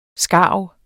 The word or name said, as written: skarv